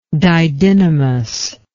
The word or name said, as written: didynamous